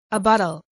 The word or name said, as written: abuttal